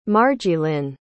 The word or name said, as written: margielyn